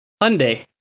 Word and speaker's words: Hyundai: Hyundai. It's pronounced like Sunday.